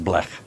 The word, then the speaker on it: blech: Bletch, I never liked it. – I think it's pronounced blech. – Blech. – But it's spelled blech.